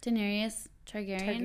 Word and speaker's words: Daenerys Targaryen: Daenerys Targaryen? – Targaryen.